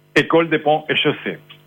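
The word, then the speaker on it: École des Ponts et Chaussées: École de Pont-et-Chaussée, París.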